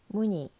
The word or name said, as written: 무니